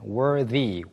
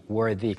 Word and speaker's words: worthy: 훌륭한 또는 자격이 있는을 가리키는 worthy, worthy. 그냥 y가 빠진 형태는 worth 발음인데 y가 붙으면서 the 발음이 되어서 worthy가 되고요.